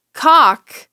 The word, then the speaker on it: caulk: Well, you know, with chalk, you don't pronounce the L either. So one could say caulk, you wouldn't pronounce the L in either.